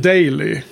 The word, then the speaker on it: Daly: Daly. Ja. Eller hur uttalas hennes namn? Jo, det låter rätt.